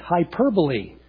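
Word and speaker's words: hyperbole (ὑπερβολή): The word in the original language here is actually pronounced hyperbole.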